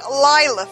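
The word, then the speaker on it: Laila: it's L-A-I-L-A, but it is pronounced Lila, folks, in Norway, in the Epcot Pavilion of Norway.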